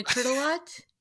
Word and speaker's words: Tourtellotte: Got it almost pretty on. Tourtellotte. So always thinking okay. About where do turtles park in a Tourtellotte and then Palumbo.